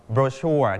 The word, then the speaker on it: brochure: คำนี้พิเศษมากนะครับคำที่เรารู้จักคำว่าโบชัวเนี่ยคนอเมริกันนะครับอังกฤษแบบอเมริกันจะอ่านว่า brochure เน้นที่พยางค์ที่2สีชมพูที่ครูเน้นไว้ brochure แต่คนอังกฤษเขาจะเน้นที่พยางค์แรกนะครับต่างกันเขาจะอ่านว่า brochure brochure brochure and brochure